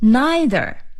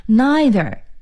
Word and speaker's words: neither: 既不，首先我们可以说 neither, N-E-I-T-H-E-R, neither, neither, I 的音啊， neither。美音发音可以是 neither Neither，这是既不怎么怎么样。